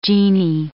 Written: djinni

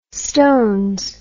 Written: stones

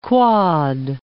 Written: quad

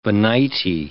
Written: Venite

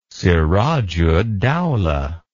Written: sirajuddaula

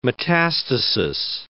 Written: metastasis

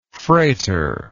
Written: frater

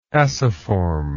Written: aciform

